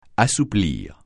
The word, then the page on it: assouplir: francais